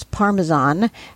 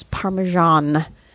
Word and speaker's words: Parmesan: That's probably why so many Americans mispronounce Parmesan as Parmesan. Parmesan is the correct pronunciation.